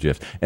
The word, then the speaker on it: GIF: And the web was suddenly full of animated GIFs. And did you hear the guy that invented GIF said, yes, it's GIF. – Yeah. – With a soft G.